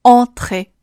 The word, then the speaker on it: entre: 下面 entre，相似的发音难点，t 是一个辅音全，a 依然保持上面所提到的口型，咧开，上下幅度稍微小一些。 a tre，前面的 on。是需要把嘴尽量张大的，舌头一定要往后缩。换一种说法就是发音靠后。 entre。